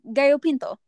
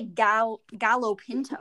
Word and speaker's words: gallo pinto: Well, yeah, it's gallo pinto. That's just how you say it. You don't say gallo pinto.